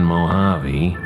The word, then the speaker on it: Mojave: The Spanish pronunciation Mojave, in its various spellings, means beside the water.